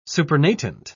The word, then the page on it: supernatant: British English